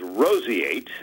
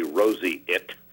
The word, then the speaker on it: roseate: The word of the day is roseate. Roseate. I guess you could also say roseate. There's a little tiny wiggle room there on the pronunciation. R-O-S-E-A-T-E. R-O-S-E-A-T-E.